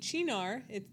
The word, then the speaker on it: Cynar: And you need Chinar. That's spelled C-Y-N-A-R, which is an artichoke liqueur, which you're going to think is crazy.